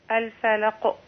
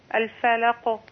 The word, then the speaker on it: الفلق: الفلق. – ايوه. ولا تختميها بهمزه. اصبري اصبري اسمعي يا اختي. – اصبري يا اختي، لا تختميها بهمزه. الفلق. تفضلي. – الفلق.